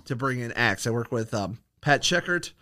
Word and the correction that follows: Sheckett: Sheckett, excuse me, I mispronounced his name. For the longest time, I was calling him the wrong name. I was adding an R into his last name, and he didn't correct me for four episodes.